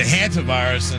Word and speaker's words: hantavirus: And this morning when I was watching the news, I realized I was pronouncing that wrong on the show last night. I apologize. It's the hantavirus.